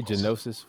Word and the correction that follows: Gnosis: Gnosis. – It's Gnosis, but there's a G there. – It's a silent GIF.